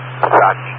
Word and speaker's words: Sakki: And Saki Computer, S-A-K-K-E. – S-A-K-K-I, Saki Computer, S-A-K-K-I. And this is 22 Hempstead Turnpike B, Farmingdale. Saki, S-A-K-K-I, and it's 22 Hempstead Turnpike B.